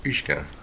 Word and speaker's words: uisge: It's U-I-S-G-E, and it's pronounced uisge.